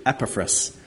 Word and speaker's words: Epaphras: Epaphras, our beloved faithful servant, fellow servant.